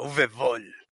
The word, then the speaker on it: Uwe Boll: Ay, el dios Uwe Boll, porque seguramente es como alemán o de por allá y se pronuncia Uwe Boll.